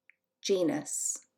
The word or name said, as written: Genus